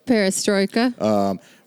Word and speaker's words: Perestroika: Like there was like the, I really like that clip of like when they introduced like, I think Perestroika, I think is how you say it. – Yeah. – Like a Perestroika.